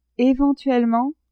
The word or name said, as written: eventuellement